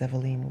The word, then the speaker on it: Eveline: My name is Eveline, which is the French pronunciation of Evelyn.